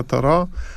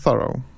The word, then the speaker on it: Thoreau: My mówimy w Polsce Thoreau. To jest uświęcona już zwyczajem, tradycją wymowa i właściwie w większości krajów europejskich taka wymowa obowiązuje, czyli po prostu mówimy z francuska. Natomiast warto wspomnieć, że Amerykanie i najpewniej sam Thoreau wymawiał swoje nazwisko Thoreau z akcentem na pierwszą sylabę.